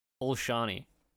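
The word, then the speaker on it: Olšany: The cemetery was shot in Olsani Cemetery. I'm probably pronouncing that incorrectly. Okay. Olsani Cemetery. There's Olshani. – Olshani. – Yeah. – Okay, Olshani Cemetery.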